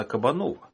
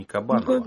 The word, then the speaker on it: Кабанова: Когда погибает в итоге Катерина, вспомните кабаниху, кабаниха, кстати, я прочитал, что ударение-то она Кабанова, а не Кабанова.